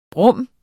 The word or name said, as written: brum